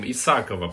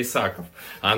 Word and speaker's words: Исакова: Я помню, э, Вероника, как когда Соня пришла, я назвал ее э, с другим ударением Исакова, потому что у меня в гандбольном клубе, где я работал, был гандболист такой Илья Исаков. А она сказала, нет, я Исакова. И потом повторяла, поправляла меня несколько раз.